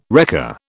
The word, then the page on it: wrecker: British English